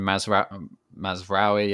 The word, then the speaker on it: Mazraoui: And you mentioned Mazraoui, yeah, that's how you say it.